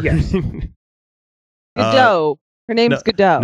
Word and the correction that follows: Gadot: Nope, she pronounced it. She pronounced it at one of the screeners where she said it's Gal. It's a hard T. It's not a soft T.